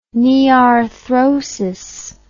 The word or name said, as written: nearthrosis